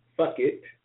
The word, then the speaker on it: Phuket: But, you know, for those of you who are interested, there's an annual festival in and this is the right pronunciation. Fuck it, Thailand. It's a huge, yes, I said it. The festival is in Fuck It, Phuket, Thailand.